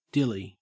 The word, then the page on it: dilly: Australian English